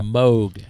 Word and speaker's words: Moog: Moog. Rhymes with Vogue.